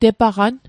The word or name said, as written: tepaqan